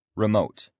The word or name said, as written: remote